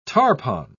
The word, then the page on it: tarpon: British English